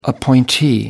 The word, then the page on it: appointee: American English